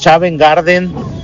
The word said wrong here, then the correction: Savage Garden: Tenía unas baladitas bien padres. Ahí si la tendrás, por favor. Y ahí, corrígeme cómo se pronuncia. – En el segmento Música en Inglés, Savage Garden.